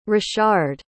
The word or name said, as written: rashard